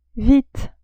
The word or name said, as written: vite